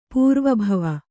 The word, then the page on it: ಪೂರ್ವಭಾವಿ: pūrva bhāvi